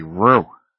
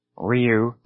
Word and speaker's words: Ryu: They might say ryu, or a lot of people say ru, just trying to muddle through. And that is unbelievably lazy. Ryu, roll your R a little bit. Emphasize the Y, emphasize the U. And there is a single character to represent R-Y-U. Ryu, ryu, toga kure ryu. It is not ru, it is not ryu, or ryu.